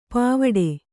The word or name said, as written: ಪಾವಾಡೆ